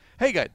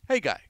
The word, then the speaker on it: Haggai: Hey, guy. That's how he pronounces his first name, which is spelled H-A-G-G-A-I. Hey, guy.